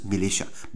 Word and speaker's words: militia: 相信我，它就叫 militia，就是 militia，不是 my militia，militia。